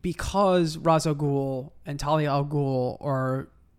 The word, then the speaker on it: Ra's al Ghul: You know what's funny, by the way, is that we use the proper pronunciation, Ra's al Ghul.